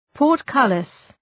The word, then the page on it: portcullis: pɔ:rt’kʌlıs